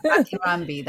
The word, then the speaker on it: Akinronbi: And I am so excited to have with me on Dr. I'm going to say it wrong. So, Akinronbi. Hi, I'm Dr. Akinronbi. – Akinronbi, that's right. So, Dr. Akinronbi is a psychiatrist joining us from New Jersey.